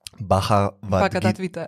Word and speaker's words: Bhagavad Gita: Ja, to je v tej, po, po mojem, bolj poznaš uh, to indijsko staro knjigo, Bhagavad Gita.